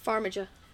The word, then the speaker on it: Farmiga: Vera Farmiga? That name? – Farmiga.